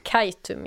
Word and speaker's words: Kaitum: Nu i sommar så ska det till, eh, hur uttalar man det? – Kaitum, ja.